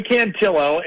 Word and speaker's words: Cantillo: Yeah, Joey Cantillo, and okay.